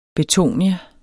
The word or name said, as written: betonie